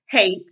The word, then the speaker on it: Haight: So Miss Tasha Haight Hate, I think it's pronounced hate.